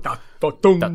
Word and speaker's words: Tatum: Tatum con l'accento sulla U. Tatum.